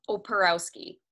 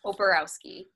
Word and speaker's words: Oparowski: Okay, ready? How it's spelled. Oporowski. – Oporowski. – Yes. Oporowski. – All just right. Like it's spelled.